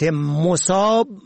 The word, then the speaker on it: مصاب: مصاب به ضم اول به معنای مصیبت زده، سختی دیده، مصیبت رسیده. مصاب به فتح اول جمع مصب به معنای محل، مورد، موضع. مصاب به کسر اول به مفهوم سیراب، پر و فرد سیراب و پر شده از آب.